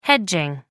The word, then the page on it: hedging: American English